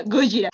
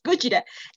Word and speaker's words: Gojira: Según el güey al que me estoy dando, y no es un CEO, desgraciadamente, Godzilla, para empezar, se pronuncia Gojira. Gojira.